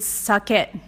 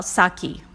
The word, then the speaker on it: sake: So Japanese rice wine is sake, not sake.